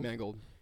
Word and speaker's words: Mangold: I don't know how to say his name. Mangold? – Mangold.